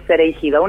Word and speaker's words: Cereigido: Y Pero bueno, complica, complica ahí la diéresis, complica que haya dos vocales juntas, es sereigido.